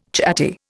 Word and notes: chatty: Indian

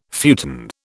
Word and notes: futon: British English

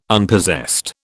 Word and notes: Unpossessed: British English

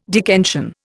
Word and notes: Dickensian: Indian

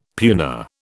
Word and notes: puna: British English

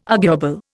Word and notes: arguable: Indian

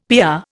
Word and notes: PR: Indian

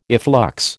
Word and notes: efflux: American English

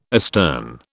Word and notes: astern: British English

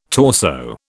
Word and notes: torso: British English

